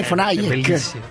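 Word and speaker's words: von Hayek: Sì, ma mi ritrovo nelle idee di Fonage. – Chi la dico questo? – Danna. – Semmai è Fonajek. È Fonajek. – Sì, sì, hai ragione, Fonajek.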